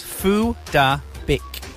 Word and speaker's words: Phuc Dat Bich: And I'll pronounce it as you would pronounce it in Vietnamese. – Yes. – Phu Da Bích. – Yeah. – But if you, am I allowed to spell it? – Yeah. – So it's spelled Phuc Dat Bich.